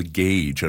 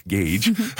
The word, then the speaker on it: GAGE: is what we call the GAGE. And I think you mentioned it originally. It's the Geospatial Agroecosystem Inference Generator, and it's not pronounced GAG, GAGE.